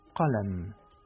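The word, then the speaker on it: qalam (قلم): Se pronuncia qalam.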